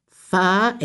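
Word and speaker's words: whā: fa, exactly the same, different spelling, W-H-A, F-A, fa, fa, rima, rima, lima, ono, ono, same pronunciation, same spelling.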